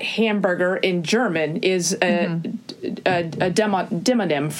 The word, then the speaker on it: demonym: No, demonym. It's spelled like demon M, like D-E-M-O.